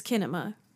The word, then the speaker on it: Kinema: And yes, I said that right. It's Kinema. With a K.